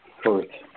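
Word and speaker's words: Fürth: Firth, F-U-R-T-H. It's pronounced Firth for most of you.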